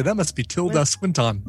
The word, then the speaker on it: Swinton: Tilda Swinton? How do you say it? – Swinton. – All right, there you go. I like Swinston, but Swinton. I think Swinton is accurate.